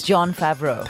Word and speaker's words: Favreau: It's John Favreau.